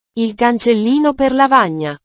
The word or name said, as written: il cancellino per lavagna